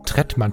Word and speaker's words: Trettmann: Ich hatte Trettmann nur ganz Trettmann, spricht man ihn so aus? Ja, ne? – Ja, Tretti.